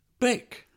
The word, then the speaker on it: Vic: Interestingly, my friend Pep, pronounced Pep, lives in the mountains near Barcelona in a village called Vic, pronounced Bic.